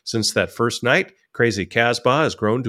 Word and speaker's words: Casbah: Did I say that right? Like the song? – You did. Casbah? – Okay, good, good.